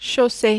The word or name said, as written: chaussure